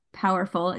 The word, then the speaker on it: Poherful: And she is the creator behind Powerful. It's just how you say it, right, Emma? But yes. She spells it P-O-H-E-R-F-U-L.